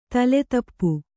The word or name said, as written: ತೋಲುತಪ್ಪು